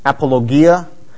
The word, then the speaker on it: apologia: It's according to Crosswalk's pronunciation, it's apologia, and it means to make a defense.